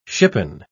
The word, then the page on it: shippen: British English